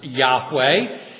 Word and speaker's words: Yahweh: And then in Hebrew, God's proper name is Jehovah, or sometimes pronounced Yahweh.